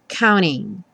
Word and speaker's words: counting: E o counting muitas vezes vai ter a pronúncia reduzida para counting, ou seja, o T some e você só escuta mesmo o N.